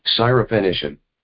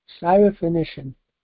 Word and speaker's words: Syrophoenician: Syrophoenician. – Syrophoenician.